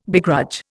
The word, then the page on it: begrudge: Indian